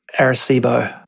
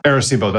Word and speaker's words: Arecibo: Yeah, most people say it as Arecibo. – Arecibo, that's right.